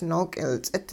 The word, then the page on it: nook'ilts'it: hupa